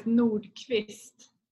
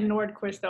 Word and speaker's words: Nordqvist: You pronounce it Quist. – Yeah. Back home is Nordqvist, but I said Nordqvist over here. It's just easier for everyone.